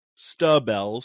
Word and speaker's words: Stubbell: And even though that looks like stab, it is pronounced Stubbell.